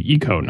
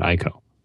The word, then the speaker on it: Ico: And the first character is pronounced like the letter E, like E. And the second character is pronounced Ko. So it should be Ico, not Ico.